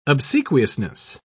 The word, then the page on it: obsequiousness: British English